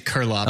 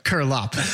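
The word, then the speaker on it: Curelop: And it's like Curplop without the first P. So Curlop.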